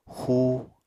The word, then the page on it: xú: Apatani